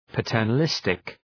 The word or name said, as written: paternalistic